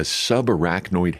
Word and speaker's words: subarachnoid: Back in 2018, he survived a ruptured brain aneurysm and, let's see if I can say this right, a subarachnoid hemorrhage. – Yep.